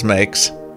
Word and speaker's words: Meeix: Mix on Twitter, M-E-E-I-X, but pronounced as Mix,